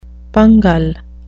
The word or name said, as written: pungle